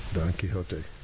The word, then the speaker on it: Don Quixote: Pronounced Don Quixote. – But you give a little key, hyphen, H-O. Okay.